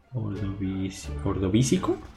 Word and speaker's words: Ordovícico: La número uno es la extinción del ordóbico silúrico. A ver, ordovi... ordovísico, ordovísico, silúrico. El segundo se lo dije bien.